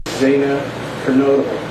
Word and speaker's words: Zanna Kernodle: Reading each murder charge for Kaylee Gonzalez, Madison Mogan, Zanna Kernodle, and Ethan Chapin. The judge at one point apologizing after mispronouncing a few of the victims' names. – Zanna Kernodle.